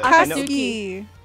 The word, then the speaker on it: Akatsuki: Akatsuki. – Thank you. I know the U's are silent. I just had to hear it once.